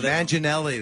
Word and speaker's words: Manganiello: Oh, Manganiello. – Jerome. – Oh, Manganiello.